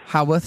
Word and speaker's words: Haworth: Did I say it right that time? – Haworth. – It's Haworth. – The worth of a laugh.